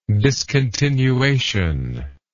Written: discontinuation

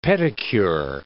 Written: pedicure

